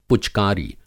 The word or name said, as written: पुचकारी